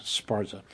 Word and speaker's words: Szpara: He writes under the name Cam Sparza, but it's spelled S-Z-P-A-R-A.